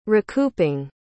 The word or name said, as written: recouping